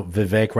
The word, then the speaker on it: Vivek: So Vivek rhymes with cake and yes.